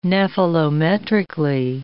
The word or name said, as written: nephelometrically